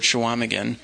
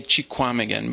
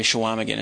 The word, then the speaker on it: Chequamegon: because you will run into the word Shawamigan. And you will not recognize it from the way I just said it. It looks like you should say Chequamegon, but it's actually pronounced in that area Shawamigan.